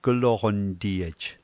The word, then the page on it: kloqntiej: slow